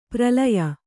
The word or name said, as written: ಪ್ರಲಯ